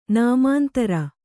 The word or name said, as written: ನಾಮಾಂತರ